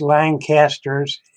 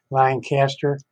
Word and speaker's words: Lancaster: Well, first of all, let me correct you on the pronunciation of Lancaster. Most Lancasters in the United States are pronounced Lancaster, but we're pronounced Lancaster. Just so you know.